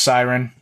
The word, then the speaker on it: Syron: And then following this chat, we've got a really cool chat with another Canadian company called Siren Golf, spelt S-Y-R-O-N, pronounced Siren.